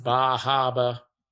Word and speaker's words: Bar Harbor: Technically, it's pronounced Bahaba.